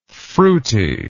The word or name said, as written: fruitiness